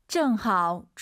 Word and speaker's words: 正好: 정하오 추취 운동一下. – 정하오 올라가면 안 됩니다. 뒤에가 일성이니까 반삼성 정하오.